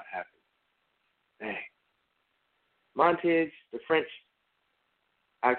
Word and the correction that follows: Montaigne: Montaigne, the French essayist, put it this way.